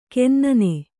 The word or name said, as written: ಕೆನ್ನನೆ